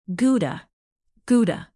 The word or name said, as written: gouda